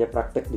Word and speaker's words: praktik: ya terkait sistemnya benar atau salah kayak gitu kalau ditulis praktek dibacanya praktek ditulisnya praktik yang benar itu ya begitu ditulisnya praktik kayak